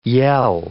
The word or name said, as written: yell